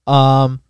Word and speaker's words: Honkai: But is it Honkai Star Rail? H-O-N-K-A-I, Honkai? – Sure.